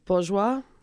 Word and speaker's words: Bourjois: oh, Bourjois.